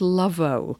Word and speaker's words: Loveau: Today, Kat, we are drinking something called, and I have no idea how to pronounce it really, I think it's Lovo. Mm-hmm. It's spelled L-O-V-E-A-U. So I'm going with the French bit for the last, because this is a sparkling water, apparently infused with raspberry, strawberry and blueberry.